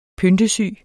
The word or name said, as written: PYNTESYGE